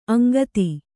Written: ಅಂಗತ

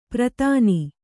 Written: ಪ್ರತಾನಿ

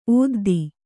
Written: ಓದ್ದಿ

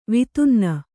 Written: ವಿತುನ್ನ

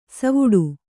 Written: ಸವ್ವಡಿ

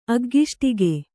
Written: ಅಗ್ಗಿಷ್ಟಕೆ

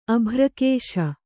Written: ಅಭ್ರಕೇಶ

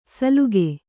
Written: ಸಲುಕೆ

